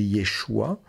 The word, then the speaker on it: Yeshua: Donc le nom de Jésus, Yeshua, on peut prononcer Yeshua, puisque le Shin, ça peut être se ou che en hébreu, selon les points qu'il y a.